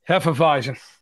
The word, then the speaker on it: Hefeweizen: Nice Hefeweizen. Say it correctly this time. – Hefeweizen. – There we go, baby.